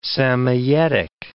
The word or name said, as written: Samoyedic